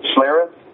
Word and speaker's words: Schlereth: Um, apparently, like this past week or something, uh, uh, ESPN analyst uh, uh, Mark, uh, how do you how do you say his last name? Schlereth. Mark Schlereth. Schlereth yeah.